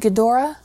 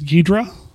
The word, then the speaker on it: Ghidorah: Yeah, it is that guy, and depending on which release, the Japanese or the American, uh, it might be pronounced different ways. – Mm. – I believe here in America it was Ghidra.